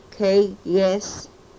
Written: keges

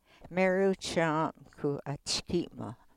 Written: meruh choom ku o chkimoh

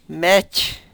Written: mech

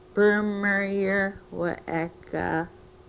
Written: prmryrh we-ekah

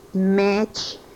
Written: mech

